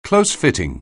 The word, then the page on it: close-fitting: anglais